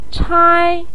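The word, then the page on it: chai1: tone 1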